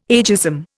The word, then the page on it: ageism: Indian